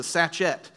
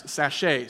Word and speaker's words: sachet: All right, what's a little bit comical here is in verse 13, last service, I said, my beloved to me is a sachet. And so that's how you pronounce it if you don't know the actual pronunciation, which is sachet.